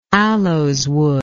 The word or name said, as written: aloeswood